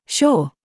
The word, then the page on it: CURE: British English